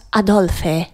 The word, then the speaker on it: Adolphe: Dus met PHE. – Dan moet je het wel uitspreken als Adolphe. – Een oer-Belgische feestdag, 6 november. De geboortedag van Adolphe Sax.